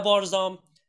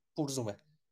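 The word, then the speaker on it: Burzum: اگه بخوام به صورت تالکین تلفظ بکنین نه بارزام نه برز اون چرت و که حالا چیزم میگه وارگم میگه هیچ کنون از اینا درست نیست درستش از لحظه تالکین برزومه اینو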